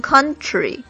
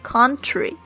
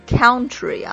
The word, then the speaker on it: country: 这里边大家需要注意几个词的发音，一个是这个 country，这个大家注意里边是一个尖角的啊，你可以读成 country，或者是嘴巴稍微大一点 country，也是有人这样子，但是千万不要读成 country 啊。